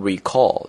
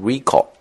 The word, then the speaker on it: recall: 注意这个单词在朗读的时候的重音的变化啊，不要读成 recall, 因为它是动词啊，在这个地方是一个名词，所以要读成 recall。